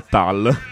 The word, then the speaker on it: Tull: Infatti non si dice manco Tull, ma Tal.